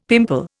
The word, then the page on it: pimple: Indian